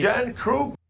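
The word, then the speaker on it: Järnkrok: I mean, they're saying Yarncroc now. – It's, what? Yeah. But he's, to me. – Yarncroc. – That's how Sandin says it. That's how we should say it.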